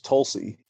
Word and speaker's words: Tulsa: And I remember, you know, the way they pronounced Tulsa, it was Tulsi on the show.